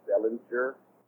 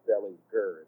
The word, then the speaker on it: Dellinger: People say a soft G in Wes's last name, so it's always Wes Dellinger. But it's actually Wes Dellinger. It's a hard G.